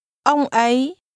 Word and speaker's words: ông ấy: Ông ấy. Ấy. Ấy. Ông. Ông ấy. Ông ấy. Ông ấy ở đằng kia. – He is pronounced with a mid-level tone. – Ông. – And a rising tone.